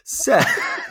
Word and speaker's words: Set: Seth. Or Set, actually. It's spelled Seth, but I think it's pronounced Set.